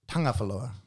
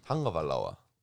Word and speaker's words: Tagovailoa: It's it's a silent NG. – Uh-huh. – Tagovailoa. – Tagovailoa.